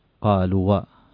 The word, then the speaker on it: قالوا: فمثلا همزة الوصل تحذف في حال الوصل لا تنطق، وفي حال البدء بها تنطق همزة قطع، لكن الألف التي بعد واو الجماعة لا تنطق مطلقا، مثل قالوا صاروا باتوا. فالقارئ لو شخص يتعلم الآن اللغة العربية يقول قالوا